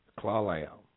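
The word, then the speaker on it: Clallam: Clawlam.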